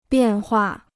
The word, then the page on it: 变化: biàn huà